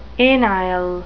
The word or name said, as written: anile